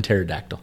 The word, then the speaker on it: pterodactyl: Because the P is silent and pterodactyl. – Ooh. Good job. Yeah, there you go. Good job. P the P silent. Is silent.